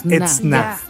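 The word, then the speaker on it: Edzná: E-D-Z-N-A. Y acento en la Etzna.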